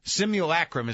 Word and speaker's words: simulacrum: Simulacrum is how it's pronounced, and I looked it up.